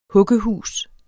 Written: huggehus

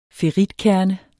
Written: ferritkerne

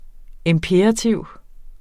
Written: imperativ

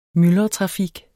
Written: myldretrafik

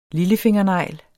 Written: lillefingernegl

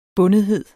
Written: bundethed

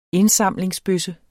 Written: indsamlingsbøsse

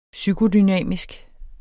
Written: psykodynamisk

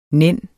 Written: nænne

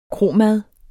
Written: kromad

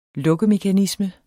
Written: lukkemekanisme

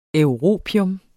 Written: europium

